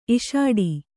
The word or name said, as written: ಇಷ್ಟೆ